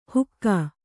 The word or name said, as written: ಹುಕ್ಕಾ